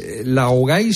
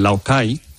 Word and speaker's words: laogai: El laogai, que así se pronuncia en chino. – ¿Cómo? – Laogai. Laogai. Se pronuncia como K, como una K.